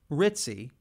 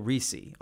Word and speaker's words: Rissi: We're going to be talking with the director, the writer, the editor, the mastermind behind Up Against Amanda, Michael, not Ritzy, because since recording that last bit, I have been in touch with him, and I find that his name is actually pronounced Michael Ricci, although he was happy to know that I was using the original Italian pronunciation, Ritzy, like Pritzy, of Pritzy's honor.